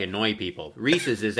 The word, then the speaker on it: Reese's: Is it Reese's or is it Reese's? How do you say that when you talk about that candy? Reese's. – Correct, Reese's. yeah, correctly, it's Reese's.